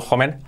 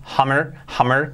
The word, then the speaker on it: Homer: Fijaos que empieza con Homer, digo Homer, Homer, que se dice en inglés, en español lo españolizamos todo, por eso a veces diré, sí que digo los Simpsons, Flanders, Homer, porque estoy acostumbrado a decirlo así.